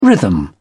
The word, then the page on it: rhythm: British English